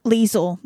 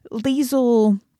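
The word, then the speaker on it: Liesel: Because quite frankly, I mean, Liesl. – Yep. I was gonna say it wrong. Liesl,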